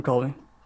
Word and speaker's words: Colby: From Colby. Covington. – Kofi Covington. – He doesn't deserve for me to say his name correctly.